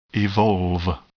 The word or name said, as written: evolve